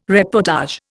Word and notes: reportage: Indian

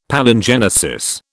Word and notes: Palingenesis: British English

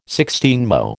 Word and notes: sixteenmo: American English

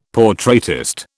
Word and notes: portraitist: British English

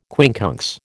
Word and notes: quincunx: American English

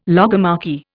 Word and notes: logomachy: Indian

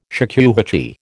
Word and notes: shakuhachi: American English

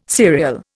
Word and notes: serial: Indian

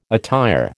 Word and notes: attire: American English

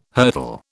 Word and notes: hurdle: British English

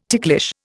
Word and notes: ticklish: Indian

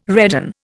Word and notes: redden: Indian

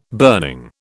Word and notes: burning: British English

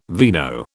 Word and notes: vino: British English

